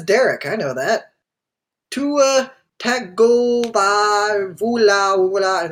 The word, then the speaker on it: Tagovailoa: Tua Tagovailoa? – Tagovailoa.